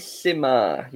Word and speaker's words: Finalissima: Finalissima.